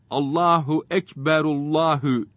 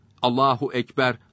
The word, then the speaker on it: Allahu ekber: Allahu ekberullahü diye okumamalıdır. Allahu ekber, Allahu ekber diye okumalıdır.